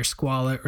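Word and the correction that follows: Squale: Just to clarify, it's pronounced squail.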